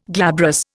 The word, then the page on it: glabrous: Indian